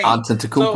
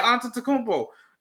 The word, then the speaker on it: Antetokounmpo: Antetokounmpo. So, yeah, Antetokounmpo.